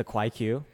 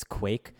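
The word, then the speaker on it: Kwaiq: I called it the Kwai Q, which is just like not how it's pronounced. It's Quake. Because it's spelled K-W-A-I, capital Q. So it's stylized as if the Q is pronounced as its own letter, as its own syllable. That's not true.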